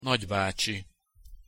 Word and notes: nagybacsi: Hungarian

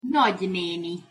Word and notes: nagyneni: Hungarian

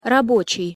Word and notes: rabocij: Russian

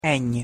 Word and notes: ny: Hungarian

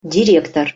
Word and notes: direktor: Russian

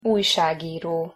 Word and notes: ujsagiro: Hungarian

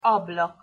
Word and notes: ablak: Hungarian

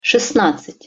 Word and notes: sestnadcat-: Russian

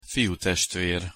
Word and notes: fiutestver: Hungarian

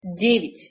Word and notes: devjat-: Russian

